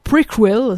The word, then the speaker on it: Prequel: Très très bon titre pour le nouvel album de Ghost qui va s'intituler, alors ça s'écrit préquel mais ça se prononce Prequel, si on veut y mettre un peu l'accent.